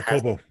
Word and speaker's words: Kobo: It's also on, I think, Kobo, if I'm saying that right. – Yeah, Kobo, yep.